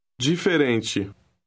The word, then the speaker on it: diferente: The letter combination D-I also is pronounced G. – Read word 12. – Diferente.